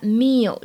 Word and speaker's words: meal: 最后的话呢，就是注意一下 meal 这个单词呢，它末尾这个音不要读成卷舌音。